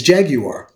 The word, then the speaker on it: jaguar: The British the, the, the true British pronunciation pronunciation for that is jaguar.